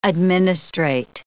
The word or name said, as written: administrate